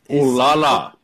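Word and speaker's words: Olalla: Owala. So am I saying that right? Owala? Or is it? – Olala. – Olala. – Ah, good. Good to hear.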